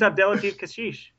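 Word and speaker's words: Abdellatif Kechiche: Also directed by Abdellatif Kashish. Nailed that name too. I've been practicing. – What the hell? – Yeah, his name's Abdellatif Kashish.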